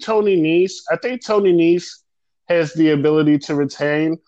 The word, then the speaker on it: Nese: Next match, Cruiserweight title, Drew Gulak versus Tony Nese. Am I saying his name right? – Yes, Tony Nese. – Tony Nese, right.